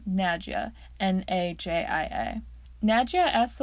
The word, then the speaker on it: Nadja: He mispronounces her name as Nadia, and she corrects him and says it's Nadja.